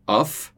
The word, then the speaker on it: off: Off contra of. Lembrando lá da diferença de pronúncia. Com dois Fs, off, com um F só, of. Quase como um V ali.